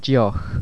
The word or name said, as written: deoch